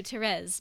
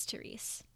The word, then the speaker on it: Therese: Carol 2, Therese, because apparently that's the way that you pronounce it. I thought that it was Therese.